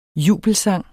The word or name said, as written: jubelsang